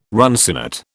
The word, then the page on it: runcinate: British English